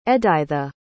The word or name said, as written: edytha